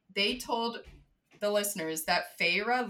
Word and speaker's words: Feyre: But also, I pronounced her name Fair until... I think in the second book or the end of the first one, like, it had the pronunciation in parentheses for something, and then it was Feyre.